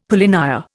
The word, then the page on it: polynya: Indian